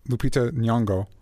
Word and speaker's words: Lupita Nyong'o: And then, I'm sorry if I pronounce it, Lupita Nyong'o.